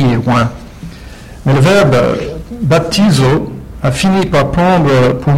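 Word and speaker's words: baptizo (βαπτίζω): Le verbe grec des textes originaux du Nouveau Testament, prononcé baptizo, avec le P, baptizo,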